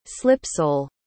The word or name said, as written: slipsole